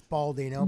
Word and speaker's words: Baldino: Am I saying your name right, Matt? I don't want to get that right. Is it too hard on the Baldino? – It's Baldino.